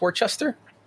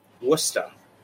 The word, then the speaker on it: Worcester: How do you pronounce Worcester? – Worcester. That's how you say it with a Boston accent. If you wanted to say it in its proper English accent, you'd say Worcester.